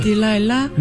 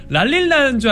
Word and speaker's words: 딜라일라: 아, 저는 몰랐는데 랄릴라인 줄 알았는데. – 랄릴라. – 근데 우리 교수님께서 발음해 주시니까 아 어떤 느낌인지 알겠다 싶네요.